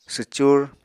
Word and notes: swcúr: apatani

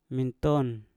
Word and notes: minton: Mpur

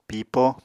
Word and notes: pipo: Apatani

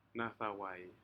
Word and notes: nadawai: Aneityum

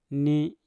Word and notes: ni: Mpur

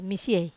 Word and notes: masiei: Wamesa

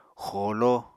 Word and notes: xooló: Apatani

